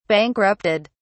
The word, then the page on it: bankrupted: British English